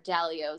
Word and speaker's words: Dalio: Or Dalio is how he pronounces it, because he's from like New Jersey.